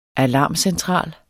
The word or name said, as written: alarmcentral